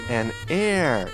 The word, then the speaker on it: heir: And again, and the pronunciation is an air.